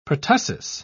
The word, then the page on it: pertussis: British English